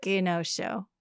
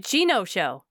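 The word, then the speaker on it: Genosha: It's not Genosho. – It's not the hard G. It would be the soft one. – What is it? A G-I? – G-I-N-O. – Oh. – Like Genosho. – Oh. – Like Gino from the Bronx.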